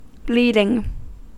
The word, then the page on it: bleeding: American English